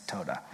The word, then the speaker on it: toda: And then I like actually looked it up phonetically and it's toda. So all these years of me teaching this and now I know how to say it. So toda, I almost said tauda again.